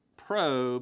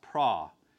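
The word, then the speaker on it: pra (πρό): If you just change this into English, it's P-R-O. And, of course, you know how we pronounce that. We pronounce that pro, but it's not pro. It comes from the Greek word omicron, and so it has that ah sound, so it's pra.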